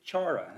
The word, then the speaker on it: chara: But, the Greek word that we find here in Galatians chapter 5, verse 22, that's translated joy, is chara. That's how I'm going to pronounce it, C-H-A-R-A.